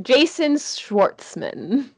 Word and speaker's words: Schwartzman: Jason Schwartzman.